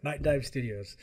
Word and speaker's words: Nightdive Studios: Nightdive Studios.